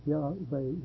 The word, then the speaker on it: Yahweh: And of course, this indicates that Yahweh would be the correct way to pronounce it.